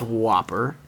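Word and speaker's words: WOPR: There is no E. It's W-O-P-R. It's pronounced Whopper.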